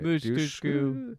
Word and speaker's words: Dushku: Dushku.